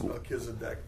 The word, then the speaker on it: Melchizedek: It's Melchizedek, but I know a guy in East Texas used to call him Melchizedek.